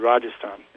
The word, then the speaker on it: Rajasthan: and there's a province of India called Rajasthan.